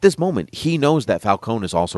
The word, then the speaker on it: Falcone: I prefer Falcone. That's the way it's supposed to be pronounced.